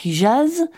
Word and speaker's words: Hijaz: dans une région qu'on appelle le Hijaz, un, un nom, un toponyme qu'on a, qu'on prononce plutôt hijaz en français.